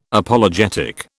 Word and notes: Apologetic: British English

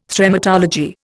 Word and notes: Thremmatology: Indian

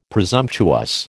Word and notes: presumptuous: American English

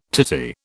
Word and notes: titi: British English

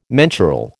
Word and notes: mensural: American English

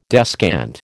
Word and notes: descant: American English

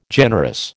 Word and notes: generous: American English

